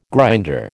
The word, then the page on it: grinder: American English